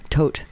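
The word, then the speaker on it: Todt: It's T-O-D-T. Yeah. I think it's Tote. Could be Todd. But no, I'm pretty sure it's Tote.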